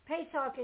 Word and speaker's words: Pesach: So turn with me to Genesis chapter 3, because to understand about Passover, about Pesach, Pesach is the Hebrew pronunciation for Passover.